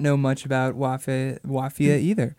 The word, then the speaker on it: Wafia: Wafia. – Which rhymes with mafia. – Rhymes with mafia, spelled like mafia, but with a W.